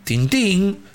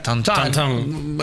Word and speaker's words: Tintín: Tiene, lo tiene muy metido dentro de la propia cultura porque es muy común que eh, los niños crezcan leyendo Tintín, que se pronuncia tan tan.